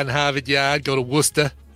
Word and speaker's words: Worcester: You don't even pronounce the R. It's Worcester.